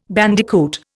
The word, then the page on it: bandicoot: Indian